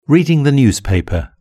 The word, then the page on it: reading the newspaper: British English